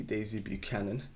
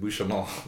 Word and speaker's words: Buchanan: Yeah, which is called Daisy Buchanan. – Yeah. – So essentially, I was pronouncing it Bouchalon.